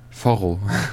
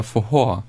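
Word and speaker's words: Forró: Also er wird geschrieben F-O-R-R-O oder so. – Foro. Okay. – Und man würde jetzt sagen, Foro. Ausgesprochen wird es aber Fohor.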